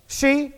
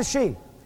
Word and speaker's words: Hsi: H-S-I, and I have no idea. Am I pronouncing H-S-I? Am I pronouncing that correctly? How's it pronounced? Shout it out. Say it again. H-S-I. How do you pronounce that? H-S-I. Yeah. She? Will that do? It'll do, thanks.